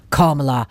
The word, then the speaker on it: Kamala: Selv siger hun jo, det udtales som et komma med et la for enden, altså Kamala.